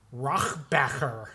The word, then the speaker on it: Rohrabacher: So, what happened when Yakov Smirnoff was listed by one Dana Rohrabacher? – Mm-hmm. – Am I pronouncing that correctly? – Yes.